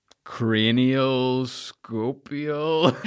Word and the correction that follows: craniosacral: Craniosacral. So the cranium being your head. – Okay. – The sacrum being at the bottom of your spine.